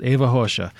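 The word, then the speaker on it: Ava Rocha: I've probably been pronouncing her name wrong, but I believe this is how it's pronounced. Eva Rocha. A-V-A, last name R-O-C-H-A.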